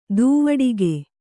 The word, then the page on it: ದೂವಡಿಗೆ: dūvaḍige